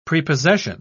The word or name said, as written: prepossession